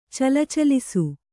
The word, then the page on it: ಚಲಚಲಿಸು: calacalisu